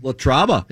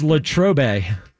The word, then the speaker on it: Latrobe: Latraba. It's female. – It's Latrobe, I believe.